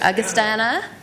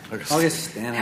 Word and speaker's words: Augustana: Karina Ramos, from Augustus. I didn't say that right. Augustana. – Augustana.